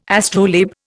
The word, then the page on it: astrolabe: Indian